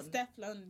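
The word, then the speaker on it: Stefflon: How do you say her name, by the way? Let's just get Steph London. – So is it Steph London or is it Steph London? – Steflon. – Steflon.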